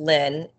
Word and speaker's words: Lyn: And she has my middle name, which is Lynn. And it's spelled a little different. It's just L-Y-N.